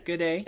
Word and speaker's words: Guede: Gede. It's how they said it on the me. Like bidet, but with a G. – Gotcha. Because it's spelled G-U-E-D-E, and so I assumed that it was Gede.